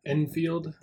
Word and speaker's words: Enfield: Emily Lingas created this awesome Einfeld, is what we call that. – Enfield will be it. – Yeah, the pronunciation is difficult.